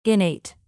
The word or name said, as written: innate